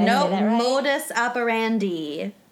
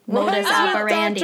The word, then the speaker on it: modus operandi: Did Modus operandi. – Operandi. – Monica versus Brandy. Rhymes with Doctor is mine. – Rhymes with Dr. Nandy. Modus operandi.